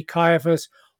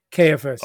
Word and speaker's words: Caiaphas: And so I did find out that it can be Caiaphas or Caiaphas.